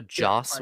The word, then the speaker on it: Joss: It's also Joss Whedon. Josh Whedon. – Joss? – O-H. Joss. J-O-S-S. – Joss. So I've been pronouncing it wrong for years? What? – Yes. It's Joss Whedon.